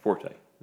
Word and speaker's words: Forte: Yeah, it's Will Fort. – Yeah. Will Forte? – Forte. I said that wrong.